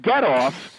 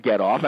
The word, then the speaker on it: Getoff: And Getoff, they should all be able to spell Getoff. I mean, you know, Getoff.